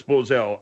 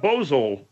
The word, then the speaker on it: Bozell: I know it's Bozell. I get scared. – Why are you getting scared? – Because if it's two syllables, the second syllable hangs me up. – What do you mean? – I'd go Bozell. Do I accent the second syllable? Do I... – So it came out Bozo? – No, it came out Bozole.